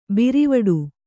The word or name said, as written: ಬೀರವಟ್ಟ